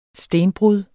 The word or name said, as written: stenbrud